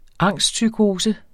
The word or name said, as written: angstpsykose